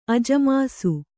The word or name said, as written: ಅಜಮಾಸು